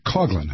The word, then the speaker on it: Coughlin: This was Father Coughlin, that's how you say his name.